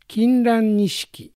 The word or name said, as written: Kinrannishiki